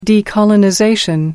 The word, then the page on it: decolonization: American English